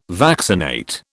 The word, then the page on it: vaccinate: British English